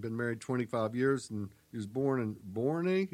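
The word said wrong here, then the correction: Boerne: Is that how you pronounce it? – Bernie, Texas.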